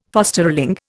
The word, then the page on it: fosterling: Indian